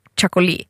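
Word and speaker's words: txakoli: Så finns det ett typ av vin som skrivs TX någonting och det ska... Det har jag lärt mig uttala. Och det är något så här chakoli.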